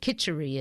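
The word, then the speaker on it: kitchari: I want you to explain what kitchery is. K-I-T. It's spelled different ways, but I think basically it's K-I-T-C-H-A-R-I.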